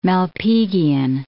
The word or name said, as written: Malpighian